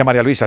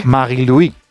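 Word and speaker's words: Marie-Louise: Bueno, no se dice María Luisa, se dice en su... – Sí. Marie-Louis.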